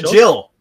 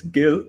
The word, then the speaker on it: Gil: So Jill? Is it a soft G? And I've been mispronouncing it this whole time. – it's Gil, because it's short for Gileri. It's a Hindi word for squirrel.